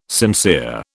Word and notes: sincere: British English

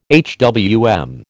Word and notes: HWM: American English

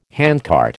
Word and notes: handcart: American English

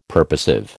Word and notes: purposive: American English

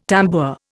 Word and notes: tambour: Indian

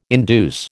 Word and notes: induce: American English

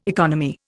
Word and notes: economy: Indian

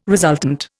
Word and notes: resultant: Indian

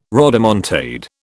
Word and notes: rodomontade: British English

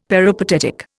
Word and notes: peripatetic: Indian

